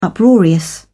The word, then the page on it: uproarious: British English